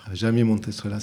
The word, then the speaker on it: Jaime Montestrela: Jaime Montestrela. – Jaime Montestrela, c'est comme ça qu'on le dit.